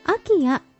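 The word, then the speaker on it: IKEA: こんにちは、シドニーから時々拝聴させていただいております、うん、 19 日の神戸ポートアイランドでの取材で、うん、イケアのことを、まあ、イケアとおっしゃっていましたが、うん、これはアキア、人によってはアキアと読むんですよと、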